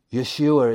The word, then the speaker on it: Yeshua: Yeshua is how you would say his name in Hebrew.